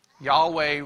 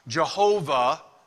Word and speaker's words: Yahweh: Now, it also could be pronounced Jehovah Rapha. I've told you before that some people pronounce it Jehovah, some people pronounce it Yahweh. We're not sure exactly which it is, but because ancient Hebrew didn't have vowels in the name. So the sounds, some of the sounds were assumed. And many people over the years have come to pronounce it Jehovah, but most scholars think that originally it was Yahweh.